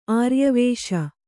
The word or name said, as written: ಆರ್ಯವೇಷ